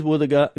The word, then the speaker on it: Wuthuga: Our local Mulgana word today is woothaga. What is a woothaga? Woothaga is a dugong. You can use woothaga in this sentence. Ngada gamanu woothaga yonga. I cooked dugong yesterday. Woothaga is spelled W-U-T-H-U-G-A.